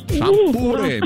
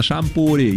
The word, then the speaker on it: шампуры: Итак, запомните, Олечка, и вы особенно, что везде, как бы вы там ни переворачивали, ударение во всех формах существительного падает на букву «у». Шампуры, металлические шампуры, жарить на шампуре, есть с шампура.